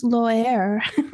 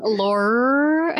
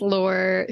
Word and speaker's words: Lore: I don't know, and these fantasy writers might be, no, her name is Loer. – Oh, Jesus. – Her name is Lore. – The R is silent.